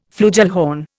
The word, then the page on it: flugelhorn: Indian